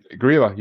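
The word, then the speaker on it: Grýla: How you pronounce it? – I said Grilla. You got it right.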